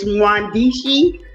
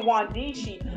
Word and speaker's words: Mwandishi: And it's Mwandishi, which is just Swahili for writers. – Okay, Mwandishi. I should have known the M was silent, but you know, when I was in elementary school, you know, pre-K and kindergarten, we were told to pronounce every syllable.